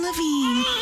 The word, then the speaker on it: Levine: Levine time. Oh, Levine. – That's a stretch. – Yeah. – That was my fault. I should have put a little more emphasis on the first syllable.